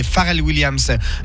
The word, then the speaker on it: Pharrell Williams: On enlève Robin Thicke et Pharrell Williams du Give Me Five. Tu vois, Mickaël, c'est comme ça qu'on prononce.